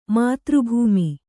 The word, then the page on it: ಮಾತೃಭೂಮಿ: kannada